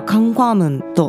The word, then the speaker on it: 광화문: 効果門というのは韓国語ではカンファームンと発音しますが京福宮の南にある門で朝鮮時代の宮城の正門なんですね。